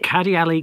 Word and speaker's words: Kadialy: How did you pronounce the name? It's really interesting. – Kadialy Kouyate, K-A-D-I-A-L-Y. – Right.